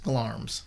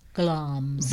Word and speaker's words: Glamis: Now, that is spelt G-L-A-M-I-S. – There's no R in there. – That's right. And there's also no I in it or any of that. – Well, how do you know it said that? Did you look it up? – Yeah, I had to look it up, and it's pronounced Glarm's.